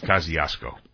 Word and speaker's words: Kosciuszko: Well, you know, all New Yorkers know that the right way to say it is Kosciuszko.